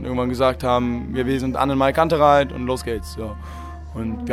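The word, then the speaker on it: AnnenMayKantereit: Ja, neben mir sitzt jetzt Henning, der Sänger der Band Annenmay Kantereit.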